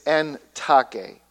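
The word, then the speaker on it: en take (τάχει): En take, or take, or in our language we change the A from a short A to a long A. No, we don't. We keep it short. Like tack.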